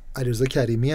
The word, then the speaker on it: علیرضا کریمی: چه خوب علیرضا کریمی